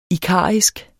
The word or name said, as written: ikarisk